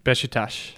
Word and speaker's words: Beşiktaş: Beşiktaş.